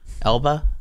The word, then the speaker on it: Elba: He goes to the island of Elba. Mm-hmm. Am I saying that right? – I think so.